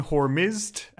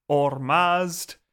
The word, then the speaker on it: Hormizd: Also, just because people might notice, I'm going to go with the pronunciation Hormizd, and that's also the spelling that's in the episode. Know that it can also be spelled Hormazd, Hormazd, or Hormizdus. And yeah, it's just basically different versions of how the name is converted into English.